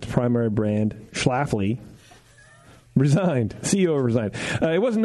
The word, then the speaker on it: Schlafly: S-C-H-L-A-F-L-Y.